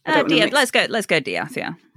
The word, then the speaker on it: De'Ath: So the Deeth name, not quite spelled like that, but DE'ATH, is a very, very old name that came to England alongside William the Conqueror and the Norman invasion. – Oh, okay. – And it was pronounced Diath, and apparently it was a very much an uppercross name, because I guess it came from the French.